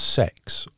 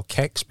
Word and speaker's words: CeX: I trawl the CEX shops, although apparently I'm told it's actually pronounced sex on the continent, but us being British, we call it CEX or Kex, because obviously we don't want to say we're going to the sex shop.